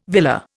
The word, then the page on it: villa: Indian